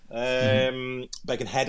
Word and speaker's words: Birkenhead: Birkenhead, I should say, pronounce it properly.